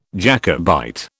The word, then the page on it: Jacobite: British English